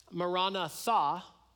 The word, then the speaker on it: Maranatha: So if you were to pronounce the word Maranatha, and if you were to pronounce it this way, Maranatha, so the emphasis on the Marana and then Tha, it would mean our Lord come.